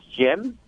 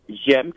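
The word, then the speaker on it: J'aime: Jen, que se escribe J apóstrofe A-I-M-E Martínez Castro. – Maravilloso, sí, por eso le dije Jaime, porque se escribe como si fuera Jaime cuando no se le pone el apóstrofe.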